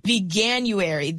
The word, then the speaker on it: Veganuary: Veganuary, that's how you say it. Okay. – Yes, for vegan January.